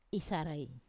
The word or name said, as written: isarai